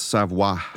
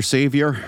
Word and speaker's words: Savoir: I think it's Savoir. – I was like, I'm not going to say this right. – Extra Savior.